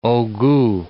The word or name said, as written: haut gout